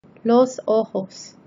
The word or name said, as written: los ojos